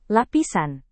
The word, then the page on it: lapisan: indonesian